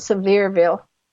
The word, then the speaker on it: Sevierville: They pronounce it Sevierville, like it's very severe.